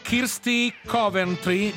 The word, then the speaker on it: Kirsty Coventry: Poi descrive Kirsty Coventry, giusto, sì, l'ho detto giusto, sì, come la figlia del presidente della Repubblica Italiano.